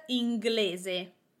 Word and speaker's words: inglese: The word inglese, it means English. We have three syllables, in, gle, ze. I am putting the emphasis on the syllable, the vowel, before the last one. Inglese, ingle, gle, that one. Inglese, on the gle, that is where I'm putting the emphasis.